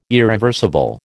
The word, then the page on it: irreversible: American English